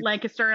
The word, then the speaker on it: Lancaster: But it is pronounced Lancaster, and I will fight you about that.